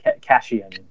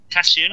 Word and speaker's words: Caitian: Cajun? I can never pronounce that. Yeah. – I've got the C-A-I-T-I-A-N. – That's it. Cassian. – Cassian? Like Cassian, yeah.